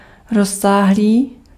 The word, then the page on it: étendu: /e.tɑ̃.dy/